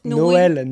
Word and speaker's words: Noël: Noël.